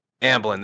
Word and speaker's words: Amblin: Is it Ambien? Or I keep mispronouncing it. Someone called me out on this. Amblin, there you go.